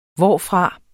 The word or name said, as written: hvorfra